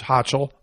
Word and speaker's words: Hochul: And a few others, but the New York governor, Kathy Hochul, I think that's, I've heard it pronounced Hochul, it's Hochul, it's the way I'm going to pronounce it here, who came out and infamously proclaimed that unvaccinated people aren't listening to God.